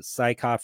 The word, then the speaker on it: Saika: I'll tell you about Saika first. I feel like I'm saying his name wrong. Am I saying his name wrong? – I think that's how you say it. I think you're doing great.